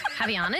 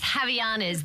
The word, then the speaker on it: Havaianas: Havaianas, is that how you say it? – Yep. – Up your footwear game with Havaianas.